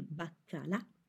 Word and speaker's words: baccalà: Per secondo, as a second course, per secondo mangiamo il baccalà, codfish, il baccalà, with the stress on the last A, il baccalà, il pesce fritto, fried fish, fritto, fried, l'arrosto, roast beef, o le salsicce, sausages.